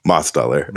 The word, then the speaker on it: Mostoller: Mostoller.